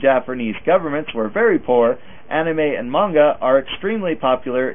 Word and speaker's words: anime: I've been taking some Japanese lessons, so if you want to pronounce it authentically, be more like anime.